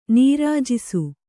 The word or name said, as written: ನೀರಾಜಿಸು